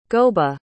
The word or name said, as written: gobha